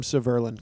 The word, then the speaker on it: Severlin: I'll call him Severlin because it rhymes with Merlin.